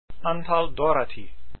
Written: Dorati

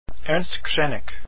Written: Krenek

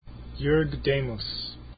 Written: Demus

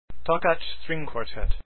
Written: Takacs

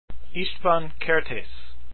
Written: Kertesz

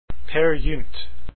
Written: peergynt

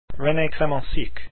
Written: Clemencic

